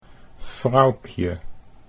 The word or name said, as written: Froukje